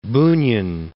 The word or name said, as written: Bunin